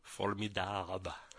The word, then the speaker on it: formidable: A little number co-written with David Johansen called Formidable. That's a French pronunciation of formidable. Sounds better if you say Formidable.